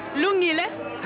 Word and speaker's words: Lungile: Lungile. – How do you spell that? – L-U-N-G-I-L-E.